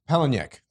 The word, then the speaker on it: Palahniuk: I don't quite remember how to pronounce his last Palahniuk. – Name. Palahniuk, the gentleman who wrote Fight Club and Guts as well, I believe.